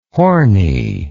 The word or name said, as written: hornie